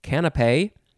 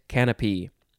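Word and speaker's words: canapé: Most people say it canape, but some people like to say canopy, but you would say it canopy. C-A-N-A-P-E, and there's an accent over the E.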